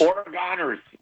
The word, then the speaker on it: Oregoners: Oregoners. – Oregoners. – Like, the people are gone.